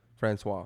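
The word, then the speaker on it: François: Francois, yeah.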